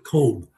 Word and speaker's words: Koob: Catherine, is it Koob? K-O-O-B. – I think she pronounces it Kobe.